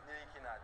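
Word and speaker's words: Ntilikina: What is the correct pronunciation of your last name? – Actually, it's Frank Nikina. Thank you, guys. Frank Nikina.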